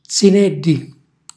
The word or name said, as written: zinèlli